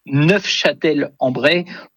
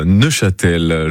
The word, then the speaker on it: Neufchâtel-en-Bray: Et en effet, pour les puristes, dont je ne suis pas, puisque je n'ai pas prononcé le F, pour les puristes, en effet, il faut prononcer Neufchâtel-en-Bray pour, en effet, peut-être se différencier de la ville de nos amis suisses.